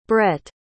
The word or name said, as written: brett